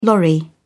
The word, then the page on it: lorry: British English